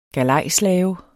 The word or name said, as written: galejslave